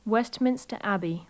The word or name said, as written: westminster abbey